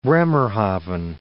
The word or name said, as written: Bremerhaven